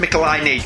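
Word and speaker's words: Michelinie: Michelini is actually pronounced Michelini. Who knew? I knew, because it was mentioned in a Marvel Letters page in the 80s. How you pronounce Michelini? Because somebody wrote in going, how do you pronounce Michelini? And that's but it rhymes with pickle my knee.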